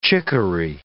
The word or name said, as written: chicory